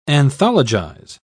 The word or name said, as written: anthologize